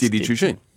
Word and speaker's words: Didi Chuxing: I mean, Uber sold off to I can't pronounce Didi Chuxing. – Didi Chuxing.